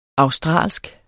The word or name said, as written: australsk